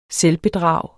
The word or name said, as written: selvbedrag